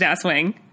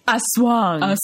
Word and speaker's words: Aswang: It's not pronounced Aswang. It's Aswang.